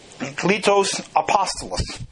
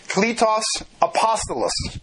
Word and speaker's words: Kletos apostolos: Kletos apostolos. You see the K-L-E, the high E, the long E, T-O-S, A-P-O-S-T-O-L-O-S. Kletos apostolos.